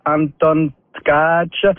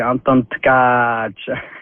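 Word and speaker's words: Anton Tkáč: C'est bel et bien Anton Tkach. Alors, si tu regardes, c'est écrit, T-K, A avec un accent. Et C avec un crochet, le A, c'est un long A, donc c'est Anton Tkach.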